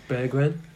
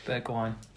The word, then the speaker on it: Bergwijn: Stephen Bergwijn. – Bergwijn.